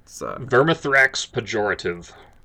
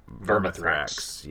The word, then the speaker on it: Vermithrax Pejorative: Vermithrax pejorative. Vermithrax.